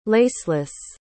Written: laceless

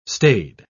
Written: staid

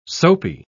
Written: soapy

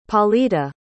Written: Paulita

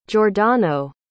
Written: giordano

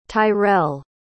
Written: tyrrell

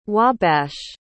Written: wabash

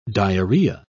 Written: diarrhoea

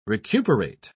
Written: recuperate

